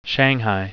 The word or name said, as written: shanghai